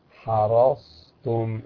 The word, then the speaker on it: حرصتم: فمثلا نجد أن البعض عند نطقه بقوله تعالى حرصتم، يقول حرصتم، يقلب الصاد سين، حرص يقول حرصتم، نعم.